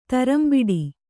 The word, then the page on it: ಥರಂಬಿಡಿ: tharambiḍi